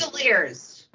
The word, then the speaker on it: Langoliers: Langliers, or how do you say that? – Kirk Cameron only makes Christian movies. – Langoliers. Is that right, Don? – Yeah.